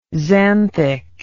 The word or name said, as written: xanthic